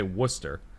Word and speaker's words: Worcester: But I'm thinking of like the town I grew up around, and where I went to college, was, we say Worcester. It's spelled WOC. – RCESTER. – So it's, I honestly forgot the R was even in it.